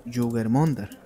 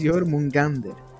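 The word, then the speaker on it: Jörmungandr: Es una serpiente gigante nombrada como Jugermundur o Jugermungandr, como le quiera mencionar, pero correctamente se menciona o se pronuncia perdón por el nombre, siempre se los he dicho, soy malísimo con los nombres, lo intento, sí, no, es Jormungandr.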